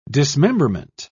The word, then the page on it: dismemberment: British English